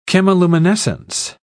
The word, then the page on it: chemiluminescence: British English